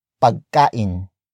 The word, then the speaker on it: pagkain: It is the object marker that introduces the non-focus noun of this sentence, and that is pagkain. Pagkain. Pagkain. Pagkain is spelled as P-A-G-K-A-I-N.